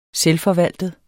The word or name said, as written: selvforvaltet